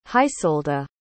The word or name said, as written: hisolda